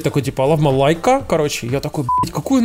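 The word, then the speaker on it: Leica: Знаете, такой, типа, прикольный ролик э, рекламный э, компании Лайка, она же Лейка, Лейка да. в простонародье. Вот. А это как она правильно, кстати, по-английски читается? – Правильно Лайка. лайка.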